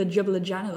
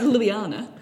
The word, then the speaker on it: Ljubljana: I was calling it the Jubla Jana the whole time. It's like Ljubljana.